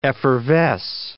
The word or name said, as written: effervesce